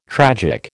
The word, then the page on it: tragic: American English